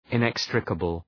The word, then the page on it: inextricable: {ın’ekstrəkəbəl}